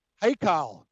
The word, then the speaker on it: Haikal: No, it's hockey. – Oh, no, it's, I know what you're talking about. Hey. Okay. Haikal, like H-A-I, Haikal.